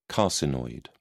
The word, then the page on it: carcinoid: British English